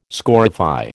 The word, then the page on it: scorify: American English